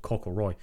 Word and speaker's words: Cockleroy: And there's a massive hill with a big sort of plinth thing at the top called Cockleroy. – Yeah. – If you look on the on the sign, it's spelled Cock Leroy.